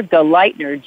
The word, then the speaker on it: Galleithner: Yeah, it's Dagmar Galeitner, G-A-L-L-E-I-T-H-N-E-R, and hyphen Steiner.